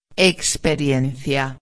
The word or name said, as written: experiencia